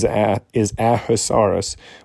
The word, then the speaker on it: Ahasuerus: His name in Hebrew is Ahasuerus, which pronounced correctly sounds like a good sneeze.